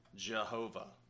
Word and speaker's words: Jehovah: And so in German, the J is pronounced like a Y. So in German, it would sound like Yahweh, but it's a combination of Yahweh and Adonai. And so what happens is when you then translate that into English, the J stayed, but we pronounce J differently in English, pronounce it with a G sound. So Jehovah.